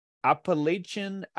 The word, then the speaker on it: Appalachia: It's Appalachia. – Okay. – Like throwing an apple at you is how you say it.